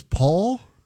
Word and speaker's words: Poll: No, but you know, his name was Paul. – P O. It's pronounced Paul. There's a pronoun, but it was spelled P O L L, as in pole.